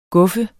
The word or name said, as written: guffe